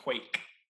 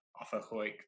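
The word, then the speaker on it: quaich: It's called a wake.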